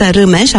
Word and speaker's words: Rimes: Am căutat și Irina spunea așa, că de fapt se pronunță Rimes, așa este corect. Ea a ales varianta Rimes pentru că i s-a părut mai simplu.